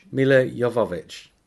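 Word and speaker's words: Milla Jovovich: And it has the best performance ever by Mia Jovovich. Is that how you say it? Jovovich? – Mila Jovovich. – Oh, thank you.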